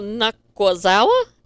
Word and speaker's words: Nakazawa: Then we see um, Marvez goes to the elite locker room, and we see Brandon Cutler and Michael Nakazawa. Did I say it right? Yeah, you said it right, finally.